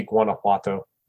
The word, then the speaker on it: Guanajuato: I think it'd be Guanajuato.